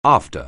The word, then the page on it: after: anglais